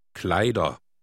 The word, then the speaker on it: Kleider: Kleider. – Notice the pronunciation of the ER ending. The R is just suggested, not pronounced very clearly.